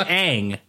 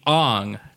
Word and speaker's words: Aang: Because it's Aang. And he was like, I think it should be Ong, because I want to put more Japanese pronunciation on it.